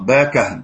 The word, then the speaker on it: باكهن: باكهن، لا باكهن، ينطق باكهن بفتح الكاف وتسكين الهاء.